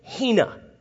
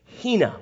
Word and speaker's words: hina: He goes, it's a conjunction, and it's the conjunction hina. It's spelled I-N-A in the English, hina.